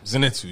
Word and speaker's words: Zenitsu: Inosuke and Zenitsu. Did I say that right? – Zenitsu, yes.